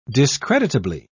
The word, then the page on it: discreditably: British English